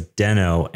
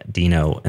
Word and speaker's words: Deno: I've always pronounced this as Deno, and I'm really happy now that I've learned that it's Dino and the logo makes so much more sense. – Okay. I was about to say, oh no, now I'm worried I've been mispronouncing it for the last year. – I mean, there's a dinosaur here. I mean, yeah, that makes way more sense.